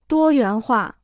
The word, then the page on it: 多元化: duō yuán huà